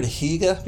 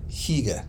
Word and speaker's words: hygge: Have you heard the word hygge before? – Hygge? – Yeah, H-Y-G-G-E. – Mm-mm. – I think you say hygge, but you make your mouth an E. Hygge. – Hygge.